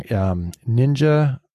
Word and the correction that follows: Nijay: He's a ninja? – No, his name is Nijay. See, I always get it wrong.